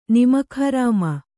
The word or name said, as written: ನಿಮಕ್‍ಹರಾಮ